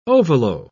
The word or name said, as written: ovolo